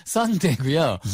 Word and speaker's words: sundae: 이거 스펠링만 보고 문화를 모르시는 분들, 미국에도 순대가 있네, 순대? 순대 아니고요, 썬데이고요. – Yes, sundae.